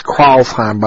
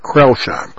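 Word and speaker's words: Crailsheim: It's pronounced Kreuzheim by the Germans, but Krellsheim.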